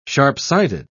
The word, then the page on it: sharp-sighted: British English